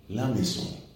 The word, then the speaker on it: maison: Y su profesora le dice, no, es que no se dice así. Se dice la maison.